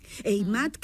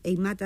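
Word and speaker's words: Heimat: Eimat, que se escribe con H, Eimat Atlántica, sí.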